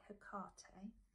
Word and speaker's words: Hecate: And when I did this the first time, I was visited by Hecate, some people call her, but she asked me to pronounce it Hecate.